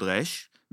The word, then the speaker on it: resh: I mean, I want to hear if they have Sabra accent, Hebrew accent, like a native Hebrew accent, something with resh, because the way that. Like those that were born in Israel, we pronounce the resh from our throat.